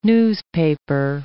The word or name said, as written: newspaper